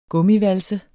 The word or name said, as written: gummivalse